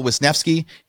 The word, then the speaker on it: Wisnefske: Today I get to interview Al Wisnefske.